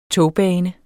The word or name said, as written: togbane